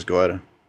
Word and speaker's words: Goetia: And you pronounce it Goetia. Is it Goetia or is it Goetia? – Could be Goetia, except I pronounce it as Goetia.